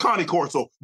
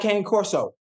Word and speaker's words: Cane Corso: It's pronounced Connie Corso. But okay, go ahead. Take off. – I like Cane Corso.